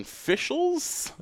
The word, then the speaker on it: Fischels: F-I-S-C-H-E-L-S. So I think I had it right. – Yeah, Fischels. Yeah, it's got to be right. It's like two of Boy Meets World star Danielle.